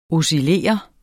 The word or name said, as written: OSCILLERE